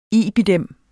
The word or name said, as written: IBID